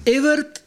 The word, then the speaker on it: Evert: Está Evert, así como si fuera E-B con B corta, Evert con T al final, Taube, Taube con B larga.